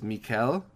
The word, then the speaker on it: Michaël: I called him Michael in the interview because sometimes I'm just a stupid American as much as I wish I wasn't. So, sorry. I'm pretty sure it's Michaël, but who knows? I hope everybody points and laughs at me when I get all of these pronunciations wrong.